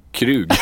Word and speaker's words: Krug: Nu blev det ett mellanuttal. Det blev verkligen Krug eller Krog? – Det blev Krug.